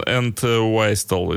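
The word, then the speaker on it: Entwistle: Пожалуйста, Джон энд Уистел. Энд Уистел, наверное, все-таки правильно произносить фамилию, конечно же, потому что Уистел — это дудочка ирландская.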